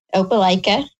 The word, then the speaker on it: Opelika: But apparently he was born in, and I might say this wrong, so bear with me, Opelika? – Uh-huh. Opelika. I think that's right.